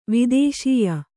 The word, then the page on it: ವೇತಸೇಯ: vētasēya; Kannada